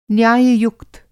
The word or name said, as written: न्याययुक्त